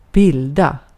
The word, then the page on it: bilda: /ˈbɪ̀lda/